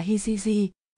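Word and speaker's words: hijiji (聖): Bài thánh, tức là những nhà thơ haiku nổi tiếng, chữ thánh, trong tiếng Nhật được đọc là hijiji,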